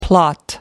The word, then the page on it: plot: American English